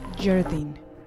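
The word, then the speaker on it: Szjerdene: A pesar de que se escribe S-Z-J-E-R, se pronuncia Jardine.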